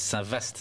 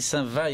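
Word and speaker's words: Saint-Vaast: V-A-A-S-T. – Voilà, certains disent Saint-Vaste, c'est pour ça qu'on dit Saint-Va ici.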